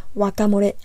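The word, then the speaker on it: ワカモレ: And you can say, 知らない。Or if your friend asks you how to make guacamole,which is my favorite, and which Japanese people would pronounce it as wakamore. They might say something like, wakamore no tsukuri kata shitte ru?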